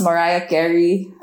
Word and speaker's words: Mariah Carey: I love Marie Carey. I guess it's... I think it's pronounced Mariah Carey.